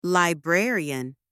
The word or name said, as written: librarian